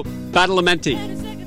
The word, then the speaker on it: Badalamenti: I've been saying his last name for weeks, and I've butchered it every time except that time. So, Angelo Badalamenti. – Yes, you got that right.